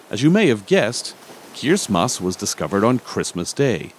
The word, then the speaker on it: Kiritimati: It's in Kiribati, so you might think it's pronounced Kiritimati. It's actually pronounced Kirismas.